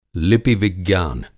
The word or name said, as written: लिपि-विज्ञान